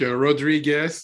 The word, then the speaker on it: Rodrigue: Yeah, you say like Rodriguez, but without a Z. So it's Rodrigue.